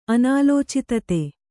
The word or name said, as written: ಅನಾಲೋಚಿತತೆ